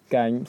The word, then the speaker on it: Cảnh: First of all, my name is Cảnh, C-A-N-H, Lê Văn Cảnh.